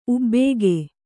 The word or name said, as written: ಉಬ್ಬಿಕೆ